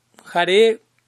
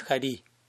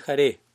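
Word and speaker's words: Hare: jare generalmente, idealmente se pronuncia como una E, no es jari, es jare, jari es jari.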